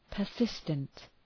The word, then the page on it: persistent: pər’sıstənt